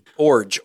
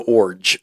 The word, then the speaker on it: Iorg: Orge like a forge. He told us this like when we met with him. – I-O-R-G. – I-O-R-G, but you say it like forge without the F, so Orge.